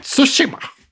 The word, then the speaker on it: Tsushima: Och det är uttalat Tsushima. – Det gör det.